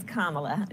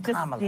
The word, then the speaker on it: Kamala: It's Kamala. Just, Kamala. Yeah, just think of like a comma and add a la.